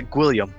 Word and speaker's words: Gwilliam: We're here to talk with my favorite local player, Taylor Gwilliam. Did I say that right? – Yeah, well, Gwilliam, but, you know.